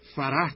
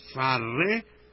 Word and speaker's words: فره: و به هر حال این واژه هم فرح تلفظ نمیشه بلکه فره و یا فرح، که با گاهی بدون اینکه هی آخرش تلفظ بشه و گاهی با تلفظ و با به زبان آمدن هی آخر گفته میشه.